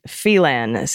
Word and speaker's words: Phelan: People in town would argue about the pronunciation. A local joke was that the reason the high school was named Serrano, neither Felon High or Phelan High would have had a great impression. P.S. I call it Phelan, since that was how our titular senator pronounces it.